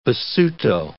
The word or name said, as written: Basuto